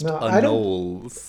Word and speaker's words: Anolis: I'm just going to say that it's actually from a Carib word that is Anoli, and so it's super pronounced Anoli.